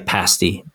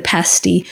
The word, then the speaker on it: pasty: And, I'm glad I stayed out of the fisticuffs last week around the pronunciation, but and I would say pasty, by the way, but I won't get us back down that path. – In the UK, that's how they say it, a pasty. But we say pasty, but some strange people up in Queensland say pasties.